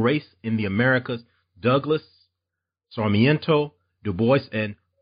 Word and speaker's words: Sarmiento: Douglass, and tell me if I pronounce any of these names wrong. Not, uh, English is my language I'm best at. Douglass Sarmiento. – Yes.